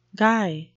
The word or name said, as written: guy